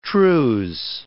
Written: trews

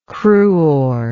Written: cruor

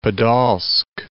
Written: Podolsk

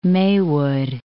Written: Maywood

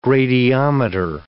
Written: gradiometer

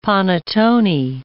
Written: panettone

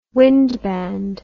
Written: wind band

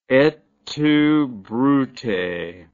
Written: et tu Brute

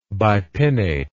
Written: bipinnately

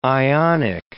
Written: ionic

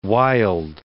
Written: Wilde

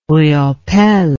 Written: Riopelle

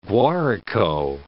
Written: Guarico

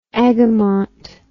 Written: agamont

